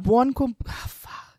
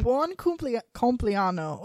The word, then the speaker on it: buon compleanno: It's like bueno, B-U-O-N. – Yeah, buon. – C-O-M-P-L-E-A-N-O. – Yeah, I get it confused with, because there's buenos cumpleaños. Cumpleaños is Spanish, but buon cumpleaños.